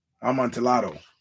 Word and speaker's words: Amontillado: But I got to get this right because I've been mispronouncing it for years. And I want to get it right because this is important. there it is. The Cask of Amontillado. Amontillado, right? Yeah. Thank you, Mr. JB.